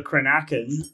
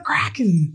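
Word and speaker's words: cranachan: this is called a crenakin, and it's an old Scottish dish. – Release the kraken. – Yes. – Nope, not that. There's another syllable, but yes.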